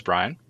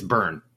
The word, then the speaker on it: Byrne: The film begins with Mother, Rose Byrne. Am I pronouncing her last name correctly? The Y is throwing me off here. – It's Byrne. – Byrne? Okay.